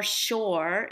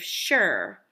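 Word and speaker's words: shore (שור): The ox, the same word, sure, shin vavresh, but it has a little bit of a twist on it in that its pronunciation is more shore instead of sure.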